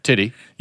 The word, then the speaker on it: Tiddy: There was a company that came out called, it was spelled T-I-D-D-Y bear instead of T-E-D-D-Y bear. – Yeah, you look it up. How did they pronounce it? – Titty. – You just gave, hey. – Go ahead and Google it. T-I-D-D-Y bear.